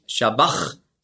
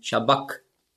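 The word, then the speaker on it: sabach: Also nicht Shabbach, sondern Shabbak.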